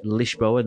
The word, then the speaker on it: Lisboa: I've been to, it's called Lisboa, which you have to pronounce it in the Portuguese way, Lisboa, not Lisboa.